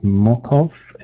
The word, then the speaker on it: Mokhov: My first track is by the artist, I think you would pronounce it Mokhov, M-O-K-H-O-V, and the album is called Future Hope, and I've chosen the track Echo Love.